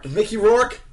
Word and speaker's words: Mickey Rourke: You like 80s movies with Richie Mork... God damn it. I can't say his name. – Mickey Rourke? – Go watch it. Rourke is actually kind of hard to say. – Rourke. It's the Rourke.